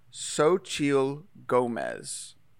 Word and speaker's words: Xochitl Gomez: and her name is pronounced Xochitl Gomez. That's spelled Xochitl.